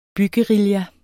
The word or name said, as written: byguerilla